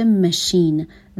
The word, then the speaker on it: machine: تلفظ ماشین توی انگلیسی میشه machine.